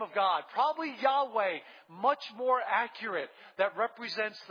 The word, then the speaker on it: Yahweh: But virtually every Hebrew scholar today believes a better pronunciation of the name of God is not Jehovah, but what? Yahweh, exactly.